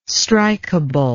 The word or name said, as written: strikable